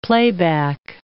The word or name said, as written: playback